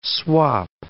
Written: swap

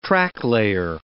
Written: tracklayer

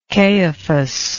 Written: caiaphas

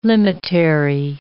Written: limitary

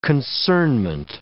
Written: concernment

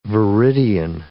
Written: viridian

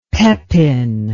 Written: pepin

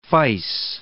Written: fyce

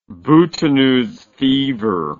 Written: boutonneuse fever

